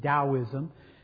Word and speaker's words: Taoism: sometimes spelled with a D, but always pronounced with a D, Taoism, that Confucianism and Taoism are probably better described as moral philosophies of life.